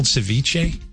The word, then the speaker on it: ceviche: Is it called ceviche? – Yeah. – Right? Is that how, am I saying it right? – Yep.